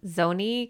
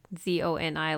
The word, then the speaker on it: Zoni: So he went out to mow his lawn, and his wife, I think it's Zoni, because it's like Z O N I, like Tony, but with a Z.